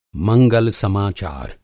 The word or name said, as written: मंगल-समाचार